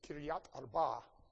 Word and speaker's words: Kiryat Arba: They captured, this one's fun, according to the Complete Jewish Bible, that would be pronounced Hebron rather than Hebron, with a k, like chemistry at the beginning and the emphasis on the latter part. It was Kiryat Arba.